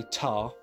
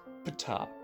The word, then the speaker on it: Ptah: It's say Ptah, but you close your mouth at the start. It's Ptah, Ptah. That's Ptah. That's it.